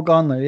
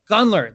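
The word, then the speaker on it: Gunler: If there's a guy like tell me if I'm pronouncing it right. It's Guntler? Gunther? – Oh, yeah. Noel Guntler, yeah. – Guntler.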